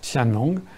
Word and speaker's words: Qianlong: ça s'écrit Q-I-A-N-L-O-N-G, hein, ça se prononce Tianlong.